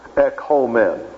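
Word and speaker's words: ekomen (ἔχωμεν): The difference is the difference between a short O and a long O. Ekomen.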